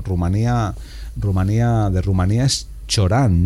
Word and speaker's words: Cioran: Bueno, yo una vez hablé con una romana y dijo Cioran. – Cioran, vale, me quedo con la pronunciación.